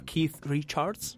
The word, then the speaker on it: Keith Richards: Fu definita da Vasco Rossi la nostra Keith Richards, ho detto bene? – Yes.